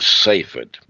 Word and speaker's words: Safed: It's spelled S-A-F-E-D, Safed.